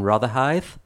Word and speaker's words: Rotherhithe: Of like a Finnish church in Rotherhithe.